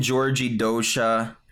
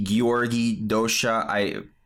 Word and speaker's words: György Dózsa: Well, it's spelled G-Y-O-R-G-Y. The O has the two dots on the top, which means the pronunciation is different. But I don't really know. I'm saying Georgi Dosha. It could be Georgi Dosha. Pardon my pronunciation.